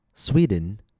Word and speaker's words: Sweden: Sweden has two syllables, Sweden.